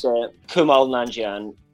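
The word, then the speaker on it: Kumail Nanjiani: That's Kumail Nanjian.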